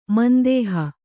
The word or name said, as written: ಮಂದೇಹ